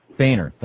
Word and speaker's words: Boehner: Boehner, thank you.